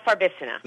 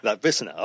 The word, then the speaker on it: Farbissina: Well, now, I'm going to say this wrong. Farbissina? – Oh, Frau Farbissina. – Farbissina, okay. – a lot of people get that wrong.